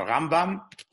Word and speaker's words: Rambam: You talk about the RambaM, it's like pebbles and bam bam, RambaM.